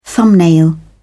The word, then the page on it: thumbnail: British English